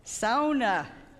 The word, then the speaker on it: sauna: And I knew how to pronounce the word sauna like a true Finlander. Sauna.